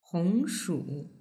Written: hongshu

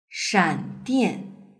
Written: shandian

